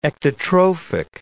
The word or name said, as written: ectotrophic